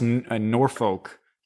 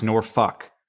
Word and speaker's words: Norfolk: It's not pronounced Norfolk. It's pronounced Norfuck.